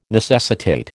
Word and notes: necessitate: American English

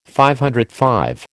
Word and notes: DV: American English

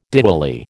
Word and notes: Diwali: American English

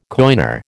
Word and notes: coiner: American English

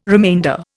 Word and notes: remainder: Indian

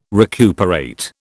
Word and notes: recuperate: British English

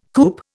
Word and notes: coupe: Indian

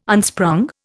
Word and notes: unsprung: Indian

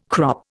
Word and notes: crop: Indian

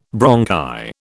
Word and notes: bronchi: British English

